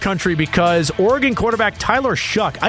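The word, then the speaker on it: Shough: I had said it as Shaw, but it's Shuck. And it doesn't look like that it should be Shuck, like Chuck, but it's Shuck is the way that you pronounce Tyler.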